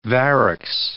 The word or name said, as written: varix